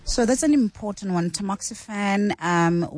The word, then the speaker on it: tamoxifen: And then another one says, "Hi the doctors, I completed the 10 years tamoxifen.